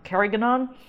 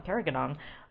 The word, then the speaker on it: Carignan: and she's another one who told me that in Mendocino they pronounce carignan carignan so I'm just going to start saying it like that all from now on, Carrigadon.